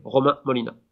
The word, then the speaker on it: Romain Molina: So yeah, the people can find me on social media like this, to my name, Romain Molina. – Brilliant stuff, brilliant stuff. Let me try and say that properly. Romain Molina, is that right? – Definitely. That's it. That's it.